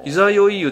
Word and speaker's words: 十六夜: そしてこの十六湯の本当の名前は、あ、これ伊佐酔湯、いざよいと読むんか。 – いざよい言うと読みます。